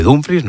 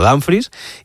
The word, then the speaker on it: Dumfries: Él mismo ha dicho que se dice así, se dice Dumfries, no Dumfries, y el club trabaja en cerrar la llegada de Diomandé y en iniciar las conversaciones con el Manchester City por Rodri.